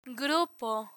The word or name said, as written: grupo